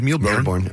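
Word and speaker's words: Mille Bornes: Mealy-born. How would you pronounce that, Nick? – Like you did, Mealy-born.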